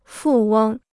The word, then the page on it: 富翁: fù wēng